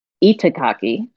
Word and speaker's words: Itagaki: It was written by Kisaki Ikutaki. And so... Well, no, I said that wrong. Itagaki, my bad.